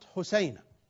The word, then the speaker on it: Hosiana: H-A-S-I-A-N-A عفوا H-O H-O S-I-A-N-A يعني بالضبط تلفظ حسينة حسينة. أقرأ مرة ثانية التهجئة التي جاءت مذكورة في هذا المرجع العلمي H-O-S-I-A-N-A تلفظ بالضبط حسينة.